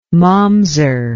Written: mamzer

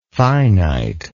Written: finiteness

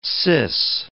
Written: sis

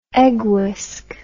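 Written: eggy

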